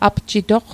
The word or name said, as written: apjitoq